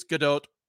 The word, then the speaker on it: Gadot: I am glad that Bruce Buffer showed tonight that he also cannot pronounce the names of non-fighters because he was like talking about Gal Gadot. Like, she's Israeli. She's not French. It's Gadot. Like, Bruce, 80% of your job is pronouncing people's fucking names.